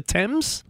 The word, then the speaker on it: Thames: That's how you say it? The Thames? – The Marcus Thames River, yeah.